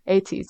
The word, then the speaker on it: ATEEZ: Um, a- ATEEZ, ATEEZ, I'm not sure how to say their name, ATEEZ, but the, yeah. – ATEEZ, yes, they're growing a lot um, now as well.